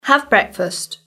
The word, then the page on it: have breakfast: British English